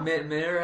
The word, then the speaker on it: Mera: M-E-R-A. – Oh, right. Okay. – Is that how you say it? Mira? – Mira.